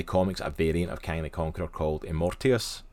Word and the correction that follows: Immortus: It's Immortus or Immortius? – Immortus, I think.